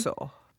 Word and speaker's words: Villasoso: It's so funny because the name is just like phonetically. Villasoso.